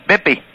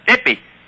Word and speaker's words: Bippy: Bippi. – Nippy. How do you spell it? P. – No. Bippi. – How do you spell it? – B-I-P-P-Y.